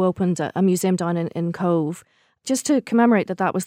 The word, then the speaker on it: Cobh: And that town, it's a little confusing because it's spelled C-O-B-H. – Yes. – And it's spelled like Cove. – Cove, C-O-V-E, yeah, like a cove.